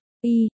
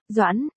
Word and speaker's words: Doãn: Tống tiên sinh, cái chữ kia không đọc là Y, đọc là Doãn, là Doãn trong phủ Doãn, không phải là Y trong Y Lợi.